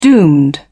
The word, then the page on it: doomed: British English